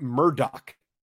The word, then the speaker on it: Murdaugh: His name is pronounced Alec Murdaugh. – No, it's not.